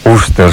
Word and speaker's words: Worcester: Sulla tavola stagnavano in permanenza alcune bottiglie di Worcester Sauce, dico bene Oliva? Worcester Sauce, si scrive Worcester, e un barattolo semipieno di marmellata da cui nessuno aveva il coraggio di attingere.